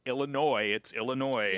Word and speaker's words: Illinois: I spent the whole time I was getting ready to come to college, I explained to people, it's Illinois. – Yeah. – And the first placement test I had here, the proctor giving the placement test pronounced the S on the end, and I just wanted to die.